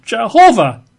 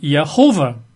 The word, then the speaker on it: Jehovah: they pronounce the word Jehovah. Or possibly if you want, Yehovah. Right? That's as good a pronunciation as any.